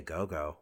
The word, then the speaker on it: Ogogo: So, but yeah, no, God, I'm gonna say Ogogo wins here. Am I even saying his name right, Christian? – You're saying it right.